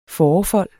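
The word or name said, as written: FÅREFOLD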